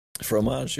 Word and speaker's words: fromage: Yeah, I saw the news, Famage. I gotcha. Oh, got him. Got him. – That's why. – Is that right? Did I pronounce it right? – Fromage, yeah.